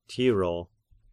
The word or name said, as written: Tiro